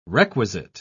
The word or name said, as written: requisite